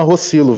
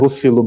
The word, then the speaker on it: Rocillo: E também a Paloma Rossilo, vice-diretora do Instituto de Referência Internet e Sociedade. Paloma, eu falei certo o seu nome, é Rossilo mesmo, né? – Exatamente.